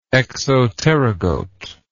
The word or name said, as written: exopterygote